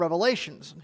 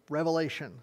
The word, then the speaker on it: Revelation: But we are getting into a very interesting portion, but a very difficult portion for most people, into the book of Revelations here. So book of Revelation, there's no S on the end of book of Revelation. Revelation, said it right, okay?